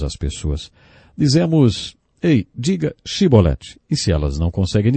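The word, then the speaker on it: Shibolete (שיבולת): Eles não conseguiam pronunciar o som CH e por isso diziam Sibolete, ao invés de Shibolete. Jefté degolou 42 mil homens, porque eles não conseguiram dizer Shibolete.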